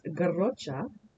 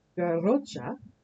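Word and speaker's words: Garrotxa: It comes from Catalonian Spanish, and the pronunciation is garrocha, garrocha.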